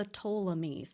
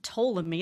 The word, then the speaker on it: Ptolemy: For one, it's actually hard to pronounce Ptolemy. It starts with a P, so I'm already thrown off there.